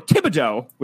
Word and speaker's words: Thibodeau: Thibodeau. The Dow. Get it out. Dow.